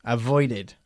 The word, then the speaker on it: avoided: ¿Por qué pronunciamos el pasado de avoid, avoided, y no simplemente avoid? – Porque acaba en de.